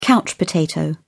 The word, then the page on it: couch potato: British English